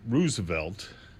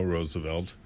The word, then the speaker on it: Roosevelt: He seems a bit hawkish in his final commentaries, but I will point out that he is aware that Teddy, as opposed to Franklin Delano, pronounced the surname Roosevelt, and that Franklin Delano Roosevelt pronounced it Roosevelt.